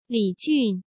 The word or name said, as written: Li Jun